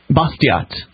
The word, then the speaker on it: Bastiat: Bastiat.